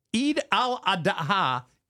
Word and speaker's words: Eid al-Adha: Tell us what this Eid al-Adha is. – Good job. That sounds very good.